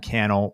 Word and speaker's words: Cannell: I'm most active on Twitter and Instagram, at Sean Cannell, rhymes with YouTube channel.